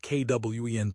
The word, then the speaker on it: Qwen: Chinese models like Alibaba's Qwen 2.5 Max, pronounced Kwen 2.5 Max, and Moonshot's Kimi K3 are closing the gap with U.S.